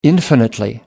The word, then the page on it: infinitely: American English